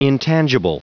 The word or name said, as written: intangible